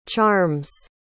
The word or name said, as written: charms